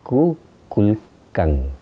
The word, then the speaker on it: Kukulkan: Ustedes saben que en maya a la serpiente emplumada se pronuncia Kukulkan.